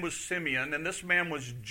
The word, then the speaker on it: Simeon: This morning, we're going to look at the last song, which is by a guy by the name of Simeon, or Simon. People pronounce it different ways. I want to say Simeon.